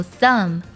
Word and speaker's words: thumb: 第三组呢，就是 m b 结尾。 b 不发音，像 climb 攀爬，还有 thumb 拇指，它们结尾的 b 呢都不发音。